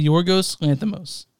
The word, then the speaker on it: Yorgos Lanthimos: Say Yorgos Lanthimos. – What? – Say Yorgos Lanthimos.